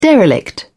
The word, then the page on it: derelict: British English